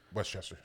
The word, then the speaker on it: Westchester: No, you're from Wester. – Westchester. Stop saying Wester.